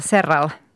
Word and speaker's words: Serral: Vi har nämligen en världsmästare i våra led, Joona Sotala, eller Serral, det ska uttalas. Serral. – No, Serral säger de nog.